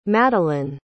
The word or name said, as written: Madelaine